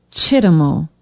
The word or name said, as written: chittamo